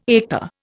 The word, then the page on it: eta: Indian